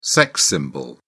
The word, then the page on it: sex symbol: British English